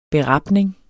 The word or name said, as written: berapning